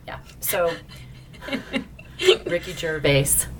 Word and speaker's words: Gervais: Ricky, I won't say his name right. – Gervais.